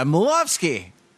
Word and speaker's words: Milofsky: It's Shayna Milofsky. – Hello. Hi. Thank you for that Polish pronunciation. It's very good.